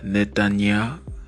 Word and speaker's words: Netanyah: My name is Netanyah, and that is spelled N-E-T-A-N-Y-A-H, Netanyah.